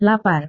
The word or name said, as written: lapar